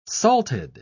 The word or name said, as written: salted